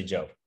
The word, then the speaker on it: Job: So the last thing I'll leave you guys with is job, J-O-B, or Job. Actually, Job. I think it's Job. Pronounced Job.